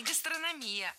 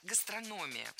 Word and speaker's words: гастрономия: У нас гастрономия тоже развивается стремительно, как и в других регионах. – Не гастрономия, а гастрономия. Ударение в этом слове падает на третий слог.